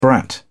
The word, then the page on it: brat: British English